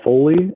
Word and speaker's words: Foley: Well, they can follow me on Twitter at NHL Foley, NHLFOLEY.